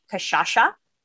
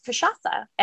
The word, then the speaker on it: Cachaça: One of my friends calls it Cachaça. Mm-hmm. My mom says Cachaça, but it's Cachaça.